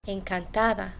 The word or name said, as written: encantada